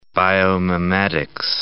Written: biomimetics

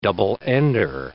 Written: double-ender

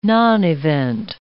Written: nonevent

